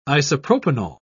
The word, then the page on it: isopropanol: British English